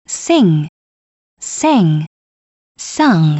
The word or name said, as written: Sing Sang Sung